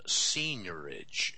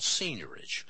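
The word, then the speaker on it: seigniorage: It's got an unusual spelling. It's S-E-I-G-N-I-O-R-A-G-E, and it comes back from English law and England, evidently. And so this process is called seniorage, and they deliver both the bonds and the currency to the Federal Reserve.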